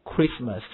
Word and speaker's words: Christmas: 好，所以在英文当中间有些 H 是可以任意的加或者减的，那么大部分的你还可以注意到大部分 CH 后面单词呢是不发音的，你比如说 Christmas 这个 C 就是完全不发音的了， H 是完全不发音的。